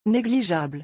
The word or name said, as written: negligeable